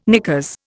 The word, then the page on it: knickers: Indian